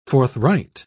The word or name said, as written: forthright